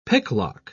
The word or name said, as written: picklock